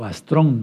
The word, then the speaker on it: astrón: Astron, o astrón más bien, astrón con acento en la O es una palabra aguda que termina en N, entonces se subraya, se pone acento, astrón igual estrella, astrón igual estrella, nomía igual norma o estudio, ¿sí?